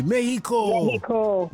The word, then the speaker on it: Mexico: When you get there, don't pronounce the X the way that we do. Just say Mexico.